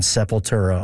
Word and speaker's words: Sepultura: You got to be the first person to ever mention Sepultura on CBC Saskatchewan radio.